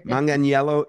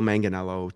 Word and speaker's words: Manganiello: I've heard all kinds of crazy things, but Manganiello is the correct pronunciation. And we'll simply tell people Manganiello, try to keep that I before the E really gets them. I've heard Mangaleo, all kinds of different things.